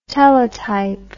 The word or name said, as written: telotype